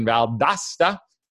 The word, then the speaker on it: Valdosta: On the 15th, we're going to be in Valdosta. That's how they say it down there. Valdosta, Georgia.